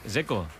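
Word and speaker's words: Džeko: Porque DZ, ¿cómo se pronuncia? – Zeko. Bruno Zeko. Dice que es Zeko.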